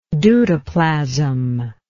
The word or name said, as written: deutoplasm